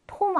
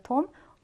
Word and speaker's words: tomb: 자, 이것도 발음이 조금 어렵죠. tomb 아니요, tomb.